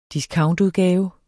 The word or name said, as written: discountudgave